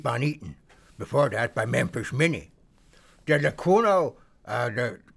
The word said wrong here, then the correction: Lecuona: Cuban boys, maybe I didn't pronounce that. Let me get that bit straight. Lecuona, Cuban boys, there we go.